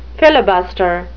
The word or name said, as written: filibuster